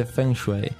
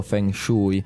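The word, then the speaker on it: Feng Shui (风水): Però in italiano viene pronunciato in un modo diverso. – Non lo so qua esattamente, cioè la pronuncia corretta è ovviamente Feng Shui. Però credo che molti italiani... leggendolo, perché sai, in italiano se io vedo questa parola la leggo Feng Shui.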